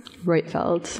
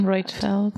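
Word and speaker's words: Roitfeld: How do you say her last name? – Reutfeldt. – So it's Corinne Reutfeldt. – Yes.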